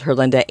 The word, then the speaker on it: Herlinda: And then my name is spelled Herlinda, H-E-R-L-I-N-D-A. Last name is H-E-R-A-S.